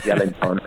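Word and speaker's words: Joelinton: but it's actually Jelinton.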